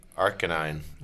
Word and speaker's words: Arcanine: Number three, Arcanine. Is that how you say it? – Yeah. – Mm-hmm. Arcanine.